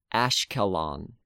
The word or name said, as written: Ashkelon